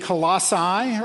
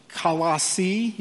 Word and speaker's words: Colossae: city of Colossae, or Colossae, you can pronounce it either way.